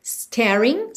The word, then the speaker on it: staring: Staring, sem pronunciar o G, blankly, blankly.